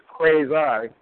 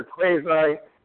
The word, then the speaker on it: quasi: It's not quasi or quasi, it's quasi. I learned that from a lot of seminars listening to law professors. So anyway, it's a quasi county overlapping the original county.